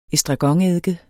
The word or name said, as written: ESTRAGONEDDIKEN